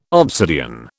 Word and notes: obsidian: British English

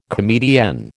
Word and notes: comedienne: American English

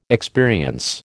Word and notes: experience: American English

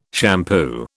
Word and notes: shampoo: British English

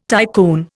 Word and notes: tycoon: Indian

